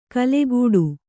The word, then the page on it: ಕೆಲಕಡೆ: Kannada